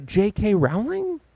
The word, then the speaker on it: J.K. Rowling: This woman, and tell me if I'm getting the name right, J.K. Rowling? – Oh, yeah, no.